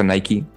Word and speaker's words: Nike: Imaginémonos que salimos de la calle y manejando nos encontramos con un hotel marca Nike o Nike. Se pronuncia Nike, N-A-N-I-K-E, Nike.